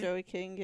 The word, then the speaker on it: Joey: Is it pronounced Joey? – Joey King, yeah.